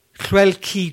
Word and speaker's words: Lleucu: Five Welsh internationals have signed up for more alongside Mo Hunt, Kelsey Jones, uh, Keris Hale, Bethan Lewis, Lleucu George. Do you like that? That's how you pronounce it. – Was it? – Lakey.